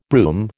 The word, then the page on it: brume: American English